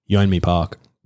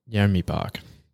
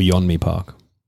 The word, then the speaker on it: Yeonmi Park: Yeonmi Park. – Yeonmi Park. There you go. – Or Yeonmi Park.